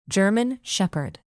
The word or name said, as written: german shepherd